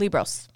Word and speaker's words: Liebross: And you interview Andrea, forgive me, how do you say her last name? Libros. – Andrea Libros, who is a small business owner.